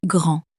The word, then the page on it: grand: French